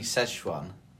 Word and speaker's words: Szechuan: Is it the Zhejiang chips or something at what? – Oriental? – Do you mean Szechuan? – I don't know.